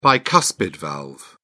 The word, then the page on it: bicuspid valve: British English